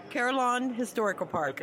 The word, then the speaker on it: Carillon Historical Park: I was just going to say, I always mispronounce that. So pronounce that for me, please. – Carillon Historical Park.